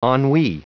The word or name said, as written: ennui